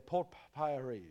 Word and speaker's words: Porphyry: Okay, Porphyry, yeah. P-O-R-P-H-Y-R-Y.